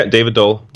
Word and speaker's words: David Doel: Yeah, David Doel. – David Doel. – It's like Bob Doel, but spelled differently.